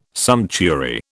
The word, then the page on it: sumptuary: British English